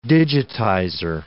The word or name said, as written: digitizer